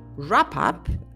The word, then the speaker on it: wrap: Y por último de este bloque, wrap up. Ojo, aquí también tenemos una consonante silenciosa, muda. Wrap up, empieza con una W, pero no la oímos, no la pronunciamos.